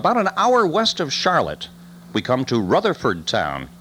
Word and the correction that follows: Rutherfordton: Okay, Rutherfordton, actually, is the way they pronounce that.